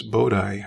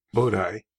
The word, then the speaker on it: Bodhi: It's Columbus Bodhi. I think that's the correct pronunciation. B-O-D-H-I.